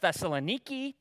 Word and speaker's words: Thessaloniki: Thessalonica, by the way, is a city in Greece, in Europe, and it's actually pronounced Thessaloniki today.